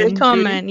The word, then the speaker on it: willkommen: So, willkommen. Did I say that right? Willkommen. – Willkommen. Yeah, danke schön.